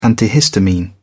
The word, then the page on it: antihistamine: British English